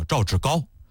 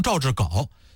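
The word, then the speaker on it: 赵志皋: 当时这个王家平呢，就提议补充两位内阁新人，一个叫张卫，一个叫赵志高。高字啊，上边一个白，下边一个本，有的念成赵志稿。其实这个字呢，有高和豪这两个读音，他不念稿啊。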